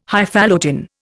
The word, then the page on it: Highfalutin: Indian